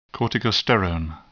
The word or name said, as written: corticosterone